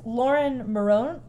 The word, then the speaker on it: Laura Marano: So it's Laura Marano. That's how we're going to pronounce it.